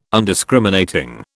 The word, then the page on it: Undiscriminating: British English